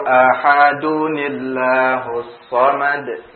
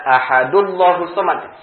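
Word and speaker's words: Ahadunillahus samad (أَحَدٌ اللَّهُ الصَّمَدُ): Maka ketika kita membacanya, sambung, Qul huwallahu ahadunillahus samad. Ahadunillahus samad. Tidak ahadullahus samad. Salah. Kalau antum baca ahadullahus samad berarti artinya di sini bukan tanwin tetapi dhammah.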